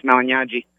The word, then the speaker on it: Malignaggi: In Italian, the GN is pronounced like a Spanish Enya, so it's Malignaggi.